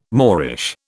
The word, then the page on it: Moorish: British English